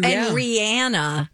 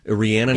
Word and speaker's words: Rihanna: Ooh, and Rihanna. – Rihanna, not Rihanna.